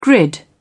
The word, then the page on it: grid: British English